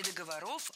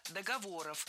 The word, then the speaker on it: договоров: Не договоров, а договоров. Вспоминаем тему выпуска. Существительные с неподвижным ударением на основе. И это значит, что ударение в слове «договор» не двигается, всегда остается на третьем слоге.